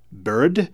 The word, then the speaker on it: Brd: All of the tracks on this album have very obscure titles, including this piece, which I believe is pronounced Bird. It's spelled B-R-D.